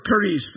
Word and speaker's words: charis: In the Greek, it's charis or charis. It's the word for grace. C-H-A-R-I-S is how we spell it in the English.